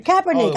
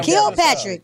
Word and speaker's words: Kaepernick: The dude, Kaepernick. – I said Kilpatrick.